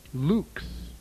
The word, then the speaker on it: LVX: On page 145 are the words Yao and the letters LVX pronounced Lux.